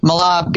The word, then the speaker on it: mahlab: I mean, you know this, of course, but Malab, guys, and it's spelled M-A-H-L-A-B, is denatured cherry pits that have been crushed.